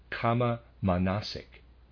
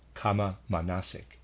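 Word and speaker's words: kama-manasic: A kind of thinking such as this is, quote, kama manasik, close quote. Let me spell that, readers aside. K-A-M-A hyphen M-A-N-A-S-I-C, kama manasik, end of readers aside.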